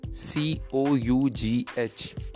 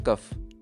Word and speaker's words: cough: C O U G H, cough, not cuff.